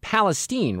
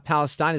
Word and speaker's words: Palestine: It's pronounced, Justine, East Palestine, right? Not Palestine. Is that correct? How are you this afternoon? – That's right, and I'm good.